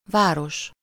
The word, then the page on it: város: /vaː.ros/